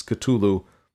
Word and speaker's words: Cthulhu: And I'm going to pronounce this Cthulhu. I've heard it pronounced in a couple of different ways, but uh, this is just the easiest one to say, so we're going to go with that.